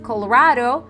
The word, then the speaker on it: colorado: And the first baby, el primer bebé, the first is primero, P-R-I-M-E-R-O, primero, the primer baby, came out very reddish, colorado. It's actually like the state of Colorado. You can say colorado with the Spanish accent. So reddish, colorado. And you spell it the same. C-O-L-O, colorado, R-A-D-O.